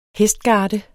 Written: hestgarde

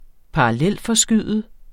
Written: parallelforskyde

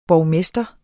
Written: borgmester

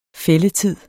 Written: fældetid

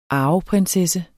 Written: arveprinsesse

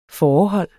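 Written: FÅREHOLD

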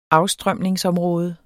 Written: afstrømningsområde

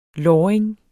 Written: låring

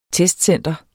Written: testcenter

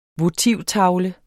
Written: votivtavle

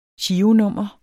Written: gironummer

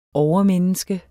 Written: overmenneske

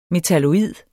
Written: metalloid

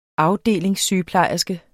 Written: AFDELINGSSYGEPLEJERSKE

